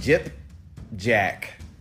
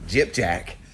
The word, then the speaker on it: Gypjaq: The gentleman by the name of Jip Jack, and it's pronounced, or I'm sorry, it's spelled G-Y-P-J-A-Q, Jip Jack, Fuller.